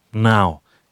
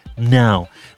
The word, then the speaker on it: Pnau: Luke Steele, the Sleepy Jackson, his project before launching the stratospheric empire of the sun with Nick Littlemore from Pnau, with a P in front of the N, silent P. I never knew how to pronounce that. I always say Pnau, Pnau, trying to Pnau it. Anyway, Pnau.